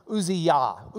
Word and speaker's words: Uzziah: Uzziah, or if you were going to call him his name in Hebrew, it'd be Uzziah. Uzziah, Uzziah. Zia. I guess that's an E sound. Uzziah. Uziyah, Uziyah, because you'd have to pronounce the yah at the end in that way.